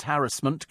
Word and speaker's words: harassment: Julie says, I love the fact you pronounce harassment correctly with the stress on the first syllable. The number of people who stress the second syllable is ubiquitous.